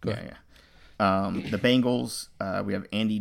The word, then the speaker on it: Bengals: It's with an E. It's Bengals. – That's what I was saying. – You're saying Bengals.